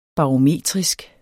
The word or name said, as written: barometrisk